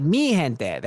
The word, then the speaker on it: Mi gente: Muy gente. Please. Oh, wait. I just said very people. How do you say it? Mi gente. There we go.